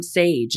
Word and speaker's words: Saje: It's spelled S-A-J-E. – S-A-J-E. – So that's why I said Sage.